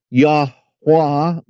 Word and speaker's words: Yahweh: This is where you get what is called the tetragrammaton, which means four letters, 1, 2, 3, 4, Y-H-V-H. Also, it is spelled Yahweh, Y-H-W-H. It is also pronounced Yahweh with the W-A-H on the end. It is also pronounced Yah, Y-A-H.